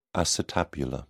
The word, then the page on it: acetabular: British English